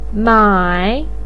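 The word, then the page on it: mai3: pinyin; tone 3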